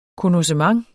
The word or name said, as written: konnossement